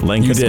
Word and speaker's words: Lancaster: Did I say it right? Lancaster? – You did. – Okay. – You did, yeah.